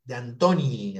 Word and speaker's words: D'Antoni: D'Antoni, a ver, ¿lo dije bien? – Philip, sí, es el